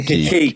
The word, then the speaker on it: Ki: And then Ki Sung-yeon, this is where we get kind of into our mispronunciations. – Right. Call him Ki. – Ki is the key.